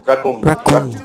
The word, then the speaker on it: Raccoon: Raccoon.